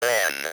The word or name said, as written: man